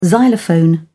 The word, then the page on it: xylophone: British English